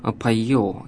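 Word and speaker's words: поео (ποιέω): Если мы возьмем греческий вариант Библии, то там слово «творец» и слово «творить» передаются словами «поэт» и глаголом «поео», если произносить его в древнем произношении.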